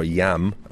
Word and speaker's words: IAM: It's his first year with the Swiss team IAM Cycling, or YAM, as I believe they're the proper pronunciation is, YAM Cycling.